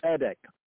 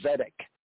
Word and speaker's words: tzedek: Now the word tzedek, we touched on it briefly, but the word tzedek, right, tzedek, or one might say in the simple English pronunciation, zedek, right, zedek, or more enunciated Afro-Asiatically, tzedek, right?